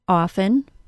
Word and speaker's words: often: I was recently asked if the word often, spelled O-F-T-E-N, has a T sound or not. This is a word I hear pronounced both ways, often, no T sound, and often, with a T sound. The Longman Pronunciation Dictionary's 1993 preference poll says that 78% of Americans prefer the no T option. Based on that, you can choose which pronunciation you prefer, and neither option is wrong, but the no T option is quite a bit more common.